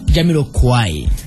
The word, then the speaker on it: Jamiroquai: ¿Es Jamiroquai o Jamiroquai? ¿Jamiroquat? ¿Jamiroquet? ¿Cómo se dice? – Jamiroquai.